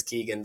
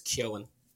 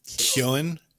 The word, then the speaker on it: Keoghan: He says Keegan because that's what everybody says, but it's Keoghan. – Keoghan.